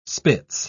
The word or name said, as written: spitz